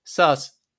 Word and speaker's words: sus: S-U-S, Sass.